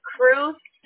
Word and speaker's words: Cruz: It is Cruz.